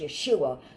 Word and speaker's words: Yeshua: In the Hebrew, Jesus, Hosea, and Joshua are all pronounced Yeshua.